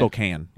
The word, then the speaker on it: Spokane: to Spokane, Spokane, in, in Spokane, is that how you say it? Spokane, yep. Mm.